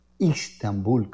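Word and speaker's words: İstanbul: Les quiero hablar de Constantinopla, que desde el año 1929 lleva el nombre de Estambul, Istambul, como se pronuncia en turco.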